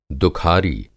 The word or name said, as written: दुखारी